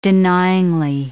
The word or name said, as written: denyingly